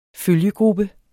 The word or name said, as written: følgegruppe